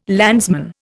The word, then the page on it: landsman: Indian